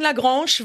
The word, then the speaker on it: La Grange: Ja, ob Lagrange oder Lagrange spricht man überall anders aus. Wir sagen Lagrange, weil es einfach, ja, ein bisschen, äh, ja, wie soll man das sagen, Benny? – Ja, erotischer klingt.